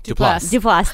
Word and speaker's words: Duplass: Duplass. – Thank you. Thank you so much. Sounds like two syllables.